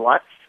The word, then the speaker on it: Lutz: His name is Kellan Lutz, and it's not a coincidence that it rhymes with gut.